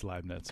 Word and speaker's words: Leibniz: Were we all saying his name wrong? – Yeah, it's Leibniz. But that's, you don't speak German.